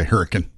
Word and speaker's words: Hurricane: It's spelled hurricane, but up there they say hurricane.